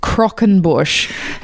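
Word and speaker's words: croquembouche: Last week, I mispronounced a certain dessert called the Cockenbush. It's Croquenbush.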